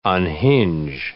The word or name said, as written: unhinge